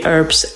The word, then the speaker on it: Earps: Earps. It would be great. Earps, though. And if you don't know, then just think of burps, and then it rhymes with that.